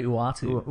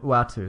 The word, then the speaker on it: Uatu: How do you say the Utah name? Utah the Watcher? How do you say that? – I say Uatu. – Uatu.